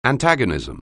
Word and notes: antagonism: anglais

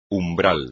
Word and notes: umbral: espagnol

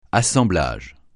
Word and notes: assemblage: francais

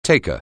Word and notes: taker: anglais

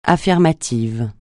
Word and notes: affirmatif: French

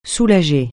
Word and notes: soulager: francais

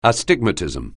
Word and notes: astigmatism: anglais

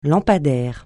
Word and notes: lampadaire: francais

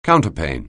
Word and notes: counterpane: anglais

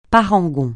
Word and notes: parangon: francais

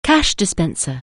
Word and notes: cash dispenser: anglais

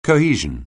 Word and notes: cohesion: anglais

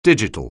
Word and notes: digital: anglais

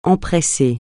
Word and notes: empresser: francais

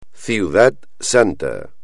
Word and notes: Ciudad Santa: espagnol

